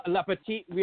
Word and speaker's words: La petite mort: La petite mort.